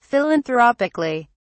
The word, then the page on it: philanthropically: British English